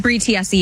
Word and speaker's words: Tiesi: How do you say it? Is it Tiesi? Bree Tiesi? Yeah, Bree Tiesi, yep.